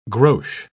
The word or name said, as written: grosz